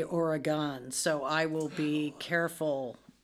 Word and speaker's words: Oregon: Yeah, it's gun. Like G-U-N. – Oregon.